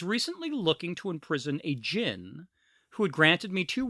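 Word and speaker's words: djinn: That's how you pronounce it, right? D G I N N. – Yeah, jinn. Right? Yeah, jinn. You're right.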